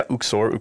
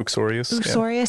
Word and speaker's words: uxorious: Uxorious? How do you say that? – Yeah. – What is that word? I don't know how to pronounce it. – Yeah, Uxorius. – Uxorius, yeah. That's like Adam's sin in Paradise Lost, yeah.